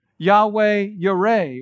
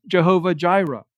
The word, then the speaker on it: Yahweh Yireh: Yahweh Yireh, or often mispronounced Jehovah Jireh.